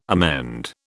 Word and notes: amend: British English

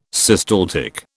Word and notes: systaltic: British English

